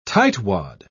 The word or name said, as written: tightwad